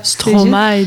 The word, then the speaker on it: Stromae: J'espère que la prononciation, c'est juste. – Stromae, désolé. – Stromae. Ok. Excusez-moi.